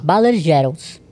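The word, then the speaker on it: Baller-Gerold: Baller Geralds. – Como que se pronuncia? Eu não sei falar isso aí. – Baller. – Baller. – Geralds. Geralds. Geralds. Geralds. – Exatamente, Baller. exatamente. Geralds.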